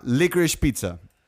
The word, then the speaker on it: Licorice Pizza: Y vamos a hablar sobre la nueva película de, como le dirían estos freaks, PTA, PTA, Paul Thomas Anderson, que se llama Licorice Pizza.